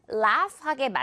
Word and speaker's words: laugh: 그러면 나 지금 웃을 수 없으니까 나 좀 그만 laugh하게 만들어 라고 해볼게요. Stop making me laugh. 이것도 뭔가 스펠링 어려워요. 어떻게 읽어야 되죠? 고민이 할 수 있는 단어인데 간단하죠. laugh, F만 처리해주면 됩니다.